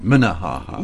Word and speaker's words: Minnehaha: Oh, is that how you pronounce it? Minnehaha. – Yes.